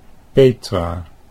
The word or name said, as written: Petra